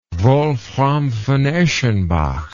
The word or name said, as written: wolfram von eschenbach